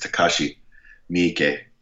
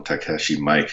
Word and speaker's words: Takashi Miike: Yeah, I think it's Takashi Miike. I always call him Takashi Mike because that's kind of how it's spelled.